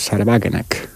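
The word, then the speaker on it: Sahra Wagenknecht: es el de Sarbagenek, o como se diga en alemán, ¿no? – Está bien pronunciado, ¿eh? – Sí.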